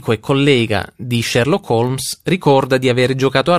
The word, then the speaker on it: HOLMES: I pezzi d'esposizione disposti in basso si riferiscono al computer della Polizia Metropolitana di Londra, denominato Home Office Large Major Inquiry System, abbreviato HOLMES, che naturalmente si pronuncia Holmes.